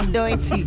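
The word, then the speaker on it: Doechii: Y a ver, ¿esto cómo se pronuncia? – Doechi. – Muy bien.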